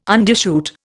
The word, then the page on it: undershoot: Indian